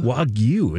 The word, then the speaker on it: Wagyu: Listen to actually how you pronounce that type of beef. – Wagyu. – Okay. Wagyu. – Wagyu, lady. – Hey. – Wagyu. – Wagyu. – Wagyu. – Wagyu is actually the pronunciation. – Wagyu. You know, it makes sense. Wagyu. It's W-A-G-Y-U, right?